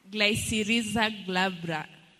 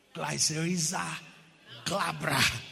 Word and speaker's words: Glycyrrhiza glabra: Glycyrrhiza glabra. – Glabra. Yes. Glycyrrhiza glabra.